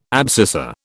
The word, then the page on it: abscissa: British English